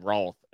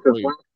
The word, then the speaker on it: Routh: How do you say your last name? Is it Roth or Ruth or what is it, Luke? – Ruth. – I completely fucked that up. Alex asked me how to pronounce it, and I said Roth, and I was completely wrong. Hey, Alex's last name is Ruth. His last name is Ruth, not Roth, Alex.